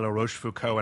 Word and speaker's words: La Rochefoucauld: Let's talk a little bit about the background of Robert de La Rochefoucauld, and I'm really proud of myself for pronouncing that correctly. – You did a great job.